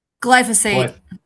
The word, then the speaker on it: glyphosate: Glyphosate? How do you pronounce that? Glyphosate. – Glyphosate. – Thank you. – I'm Glyphosate. – familiar with that one. – Oh, I thought it was glyphosate.